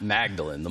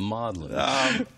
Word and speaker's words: Magdalen: The Magdalen, not Magdalen, the Magdalen. – What's the significance of that? – It's significant. The Magdalen fragment, P64, that's Papyrus 64, is the second oldest New Testament fragment that we have.